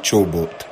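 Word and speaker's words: Csoboth: ¿Cómo se pronuncia Sobot? A ver. – Kevin Chobot. – Chobot, porque Chobot. Es una CS antes.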